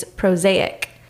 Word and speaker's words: prosaic: It is prosaic. Rhymes with mosaic.